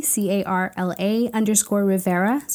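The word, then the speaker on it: Carla Rivera: And then on Twitter, if you want to hear my nerdy talk, it's Carla_Rivera. – And can you spell that? – Definitely. It's Carla with a C, Carla_Rivera.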